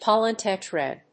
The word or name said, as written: pollen tetrad